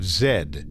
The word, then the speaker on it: Z: Fawcett was determined to find this city, which he called the City of Z, although Fawcett would have pronounced it the City of Zed.